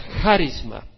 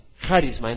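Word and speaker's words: charisma (χάρισμα): La palabra se pronuncia charisma, charisma en el griego, y quiere decir un regalo de gracia, es decir, un regalo que se recibe sin trabajar para ello.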